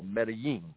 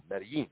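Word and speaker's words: Medellín: Okay, so if we're in Mexico speaking, the Mexican version of Spanish, the G becomes a L, Medellin, Medellin. Here in Colombia, it's Medellin, more of a G flavor.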